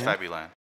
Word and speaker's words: Fabuland: Again, I don't know how to pronounce it in English, but I think it is Fabuland. – Yeah, it's Fabuland.